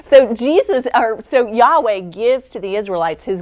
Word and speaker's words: Yahweh: We think that the pronunciation would have been Yahweh.